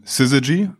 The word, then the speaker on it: Syzygy: ist aktuell CEO von Syzygy, ähm, spricht man tatsächlich so aus,